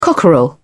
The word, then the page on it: cockerel: British English